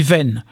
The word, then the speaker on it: gwen: D'ailleurs, ici, on dit « ven », hein, ce « w », on le prononce « ven »,